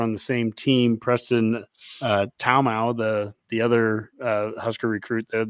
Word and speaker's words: Taumua: T-A-U-M-U-A. Taumau. – T-A-U-M-U-A. – Mazkua. Yeah, it's another UA.